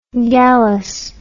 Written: gallous

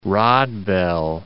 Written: Rodbell

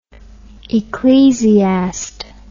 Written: ecclesiast

